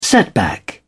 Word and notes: setback: British English

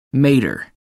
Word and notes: mater: American English

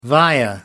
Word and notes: via: American English

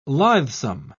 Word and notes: lithesome: British English

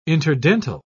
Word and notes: interdental: British English

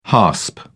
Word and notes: hasp: British English

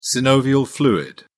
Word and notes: synovial fluid: British English

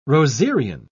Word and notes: rosarian: British English

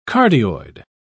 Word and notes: cardioid: British English

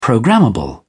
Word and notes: programmable: British English

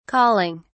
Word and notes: colling: British English

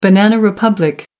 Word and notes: banana republic: American English

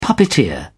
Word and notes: puppeteer: British English

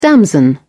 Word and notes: damson: British English

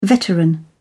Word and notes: veteran: British English

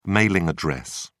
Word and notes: mailing address: British English

